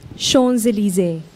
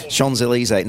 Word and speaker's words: Champs-Élysées: Is that how you say it? I've seen the Champs-Élysées. – How do you say it? – Champs-Élysées. – That's exactly what we said.